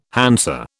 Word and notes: Hansa: British English

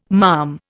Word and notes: malm: Indian

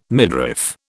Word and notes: midriff: British English

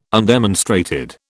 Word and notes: undemonstrated: British English

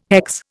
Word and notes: hex: Indian

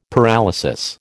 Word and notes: paralysis: American English

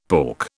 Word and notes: pork: British English

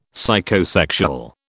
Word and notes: Psychosexual: British English

